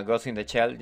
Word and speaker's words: Ghost in the Shell: Ajá, Ghost in the Shell, yes.